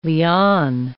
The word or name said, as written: liane